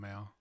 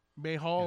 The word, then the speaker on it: Mayall: Yeah, John Mayall. – John Mayall. – Mayhall.